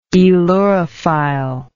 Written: aelurophile

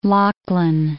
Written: Lachlan

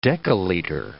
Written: dekaliter

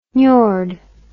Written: niord

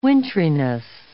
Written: wintriness

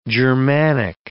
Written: Germanic

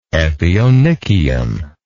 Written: epionychium

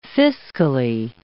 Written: fiscally

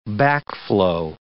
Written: backflow

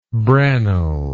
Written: branle

